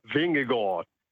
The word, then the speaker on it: Vingegaard: Vingegaard. De A wordt een O en de D wordt, die spreek je eigenlijk niet uit. En de V van Victor wordt een W in het Deens.